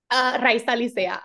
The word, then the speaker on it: Raiza Licea: I said that right, yeah? – Raiza, Licea. You were close.